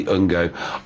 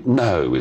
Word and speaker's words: Ngo: N-G-O. Is it Andy Ungo? I think it's Andy No is how you pronounce it. Is that right? – Yes, it is. – It's Hello. Andy No. It's a silent G.